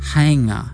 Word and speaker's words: whainga: You'll hear the word whainga. It's another kupu used for tupuna ki Ngāpuhi and sometimes pronounced hainga.